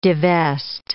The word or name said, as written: devest